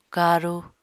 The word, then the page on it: karu: Apatani